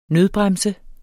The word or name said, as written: nødbremse